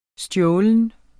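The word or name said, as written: STJÅLENT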